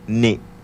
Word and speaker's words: ni: Y le toca el turno al sentido del olfato, que en este caso pues es parte de la nariz, que en lengua yocotán también una palabra muy corta que se pronuncia ni.